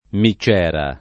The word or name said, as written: micciera